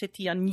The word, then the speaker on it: Titane: And it's pronounced Titane.